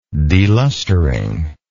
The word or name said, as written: delustering